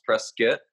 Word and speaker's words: Prescott: Arizona, Prescott, Arizona, spelled Prescott, pronounced Prescott.